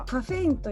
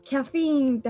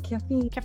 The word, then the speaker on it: caffeine: カフェインというキャフィーンって発音するらしいんですけど英語だとキャフィーンキャフィーン